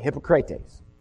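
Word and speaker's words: hypokrates (ὑποκριτής): That's the Greek word pronounced that way, hypokrates.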